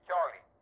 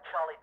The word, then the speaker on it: Cholly: is Charlie. – How do you spell Charlie? – To pronounce that correctly in English should be spelled C-H-O-L-L-Y. – Oh, like Charlie Dressen. – That's right. – Right. – In Spanish, it's spelled C-H-O-L-I.